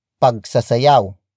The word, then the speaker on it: pagsasayaw: It is the focus marker that introduces the main topic of the sentence, which in this case is pagsasayaw. Pagsasayaw. Pagsasayaw. Pagsasayaw is spelled as P-A-G. S-A-S-A-Y-A-W.